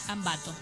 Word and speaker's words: Ambato: En las sierras de Ambato es, o es Ambato, no sé cómo se dice de Catamarca. – Ambato.